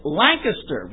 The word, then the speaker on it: Lancaster: But people in Pennsylvania call it Lancaster, with the C on the first syllable, not the second.